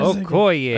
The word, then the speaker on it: Okoye: What is Okoye.